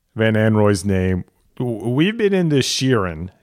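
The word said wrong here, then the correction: Shirin: you know, cheering, cheering on Sheeran, and then she said Shireen Van Anrooij, and I was like, oh, that's another one I think we've been doing wrong.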